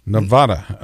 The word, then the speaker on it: Nevada: Is it pronounced Nevada? – That's right. – Nevada, okay.